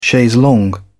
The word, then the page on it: chaise longue: British English